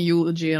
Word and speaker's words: eulogy: or a oology. And I will. – You googly. – Sorry, I even mispronouncing it wrong. Okay. But, I'll be writing a eulogy,